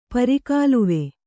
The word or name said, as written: ಪರಿಗಾಲುವೆ